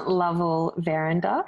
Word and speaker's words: Lovell Verinder: little bit of a long name, but it's Erin Lovell Verinder.